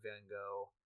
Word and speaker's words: Van Gogh: And Bill Nye in that episode plays a loving expert on Van Gogh, or Van Gogh, as it is supposed to be pronounced.